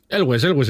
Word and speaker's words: Elwes: Nunca supe cómo se dice el apellido. – Elwes está bien dicho. – Elwes, vale.